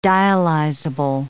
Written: dialyzable

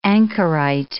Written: ankerite

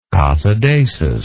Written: casadesus

